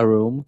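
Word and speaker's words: Roem: É que o jeito que se escreve Room é R-O-E-M, que é uma palavra mesmo holandesa, e em holandês significa fama.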